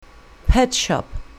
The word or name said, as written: pet shop